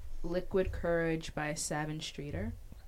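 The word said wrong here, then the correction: Sevyn: Wait, by who? – Seven Streeter. – Seven, sorry. Why did I say her name like that? – Seven.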